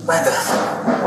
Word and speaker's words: Lampeth: Lampeth. – What? How do you spell it? – L-A-M-P-E-T-H. – L-A-M-P-E-T-H, okay.